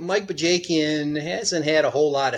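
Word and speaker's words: Bajakian: Because there's a lot of experience in Bajakin or Bajakin or however you pronounce it. I might pronounce it. – Oh, Bajakin. Right. Yeah, yeah. – Bajakin. – Yeah. – See, not even close.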